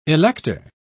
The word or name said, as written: elector